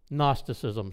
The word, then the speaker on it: Gnosticism: Gnosticism, silent G at the beginning.